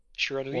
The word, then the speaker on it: Shiranui: I don't know how do you pronounce that? – You fell down some stairs saying that name. – Well, how do you pronounce it? Mei Shiranui? No, you were right.